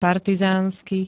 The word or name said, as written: partizánsky